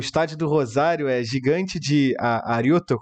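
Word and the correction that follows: Arroyito: como é que fala? – Arrochito. – Arrochito.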